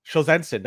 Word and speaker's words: Solzhenitsyn: Solzhenitsyn. That's the way I will pronounce it.